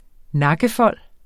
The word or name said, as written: nakkefold